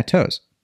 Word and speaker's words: Toews: Yeah, Toews.